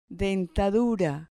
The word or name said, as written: dentadura